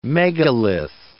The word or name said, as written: megalith